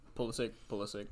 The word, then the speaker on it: Pulisic: Obviously, I'm a big fan of Pulisic, but mm-hmm. am I saying that right? – Pulisic, yes.